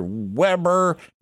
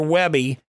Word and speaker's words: Weber: Hot and Fast Barbecue on your Weber. Not your Webby. I said it right.